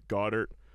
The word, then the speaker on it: Goedert: Dallas Goedert. – Goedert, like him first to score.